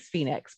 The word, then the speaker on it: Fenyx: Yeah, she's Phoenix, but it's spelled F-E-N-Y-X.